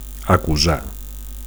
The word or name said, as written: acusar